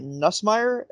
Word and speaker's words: Nussmeier: They have Jaden Daniels, Miles Brennan, and Garrett Nussmeier.